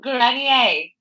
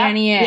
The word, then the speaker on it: Grenier: Grenier. – Yep. Grenier.